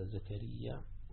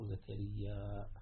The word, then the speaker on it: زكريا: وزكريا تنطق هكذا زكريا، وتنطق زكريا بالمد، يعني بالقصر وبالمد، وهو نبي من أنبياء بني إسرائيل، وابنه يحيى كما سيأتي، وأن الله عز وجل رزقه بيحيى وهبه يحيى على كبر.